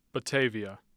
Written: batavia

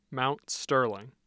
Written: mount sterling

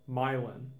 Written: Milan

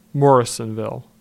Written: Morrisonville

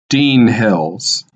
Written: Dean Hills

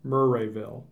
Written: Murrayville

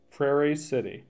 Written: Prairie City

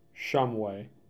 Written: Shumway

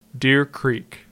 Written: Deer Creek

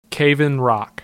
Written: Cave-In-Rock

